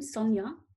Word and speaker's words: Sonja: It's my first name, Sonia, S-O-N-J-A.